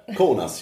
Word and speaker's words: Kaunas: And I said, cool note. – Kaunas, yeah.